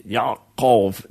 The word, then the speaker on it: Yaakov: And if we put it the way it would have been pronounced in the original, it'd be Yaakov. There'd be an ayin and a ka sound.